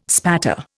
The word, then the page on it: spatter: Indian